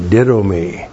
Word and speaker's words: didomi: D-I-D, long O-M-I, ditto me.